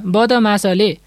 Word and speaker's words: बदमास: Sir, यो बदमास कि के भनेर कसरी उच्चारण गर्ने? यसलाई सरले एकपटक उच्चारण गरिदिनु भए हुन्थ्यो। – ल, तपाईँहरू पनि म सँगसँगै उच्चारण गर्नुहोस् है त। – हुन्छ सर। – बदमास, बदमास।